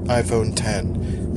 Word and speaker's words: iPhone X: It's spelled iPhone X, it's pronounced iPhone 10.